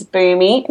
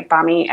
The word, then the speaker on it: Bumi: His name is Boomy. Now, it's spelt B-U-M-I. Lots of people say Bummy, and that's totally fine. But it's actually Indonesian, and it means earth.